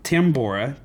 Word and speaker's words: Tambora: Yes, Mount Tambora, T-A-B-M-O-R-A.